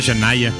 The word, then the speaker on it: Chania: Se llama Chanaya. Se escribe Chania. Pero se pronuncia Chanaya, ¿sí?